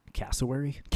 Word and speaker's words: cassowary: How do you pronounce this? – Cassowary.